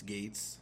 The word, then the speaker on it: Gaetz: I, I well, like, he's it's spelled weird, but it, it's Gaetz.